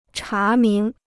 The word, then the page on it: 查明: chá míng